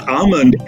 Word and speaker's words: almond: So for the word almond, L is silent.